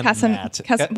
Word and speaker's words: Casamassina: Kezia McDonald and then I think Matt Casablanca. – Matt Casablanca. – Did I pronounce that wrong? – Casamassina. – Why did I say Casablanca? – Casamassina.